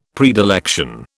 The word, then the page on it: predilection: British English